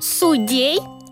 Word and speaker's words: судей: Судей? Это правильное ударение? – Да, правильное. Именно так и нужно говорить. Судей.